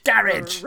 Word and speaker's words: garage: Garage. – Garage. Ra, ra, the second syllable, ra, gets the emphasis. The emphasis, garage.